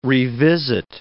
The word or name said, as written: revisit